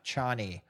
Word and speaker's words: Chani: I've heard people pronounce Chaney as Chaney, even though in my head it's a softer ah sound, Chani.